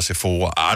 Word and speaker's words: Sephora: først der, min hjerne har aldrig nogensinde brugt tid på at skulle spekulere over, hvordan udtales S-E-P-H-O-R-A. Mm. Sephora og Sephora, I don't know. – Men det var selvfølgelig Sephora.